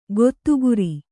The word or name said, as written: ಗೊತ್ತುಗುರಿ